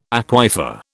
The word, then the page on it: aquifer: British English